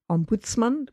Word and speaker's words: ombudsman: It comes from the Scandinavian ombudsman, which would be the emphasis on the middle part, and it doesn't really mean man.